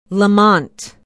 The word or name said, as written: Lamont